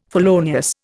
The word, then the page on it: felonious: Indian